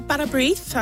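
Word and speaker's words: Bara brith: Is it Barabrith? How do you say that? – Yeah, Barabrith, I would say.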